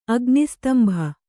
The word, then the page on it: ಅಗ್ನಿಸ್ತಂಭ: Kannada